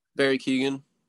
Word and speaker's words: Keoghan: Keoghan? – Barry Keoghan.